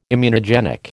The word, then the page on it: immunogenic: American English